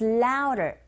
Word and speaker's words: louder: 근데 드 발음이 안 들리죠. 우어라는 모음 사이에 드 사운드가 꼈어요. 역시 티 사운드처럼 이 강몸과 양몸 사이에 드 사운드 역시 R 소리에 가깝게 들리게 되는 현상이죠. So louder, 틀린 발음이 아니라 원어민의 입에서 나오는 소리 자체가 louder 이렇게 들리거든요. 그렇게 발음해 주시면 됩니다.